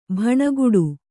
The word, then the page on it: ಭಣಗುಡು: kannada